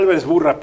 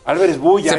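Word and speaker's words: Álvarez-Buylla: Yo creía que era Álvarez Burra, perdón. Álvarez Bulla.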